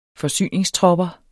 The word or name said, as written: forsyningstropper